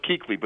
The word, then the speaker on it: Kuechly: Now, what they did do, though, was they spell his name in a way to confuse basketball fans, because it is pronounced Kuechly, but that's not your fault.